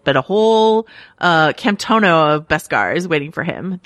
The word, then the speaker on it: Beskar: So I want to talk about, I believe it's pronounced Beskar, right? Which mm-hmm.